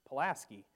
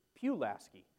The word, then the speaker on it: Pulaski: We have a Pulaski in Wisconsin, and it's Pulaski, whereas here in Virginia, it's Pulaski.